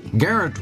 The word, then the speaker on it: Geraint: It is spelled G-E-R-A-I-N-T, but pronounced Geraint.